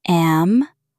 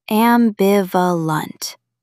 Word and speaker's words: ambivalent: Put the stress on the second syllable. Ambivalent.